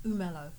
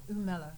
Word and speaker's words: Umelo: Umelo. – Umelo? – Mm-hmm. – Umelo. – Umelo. – Like mellow, like the basketball.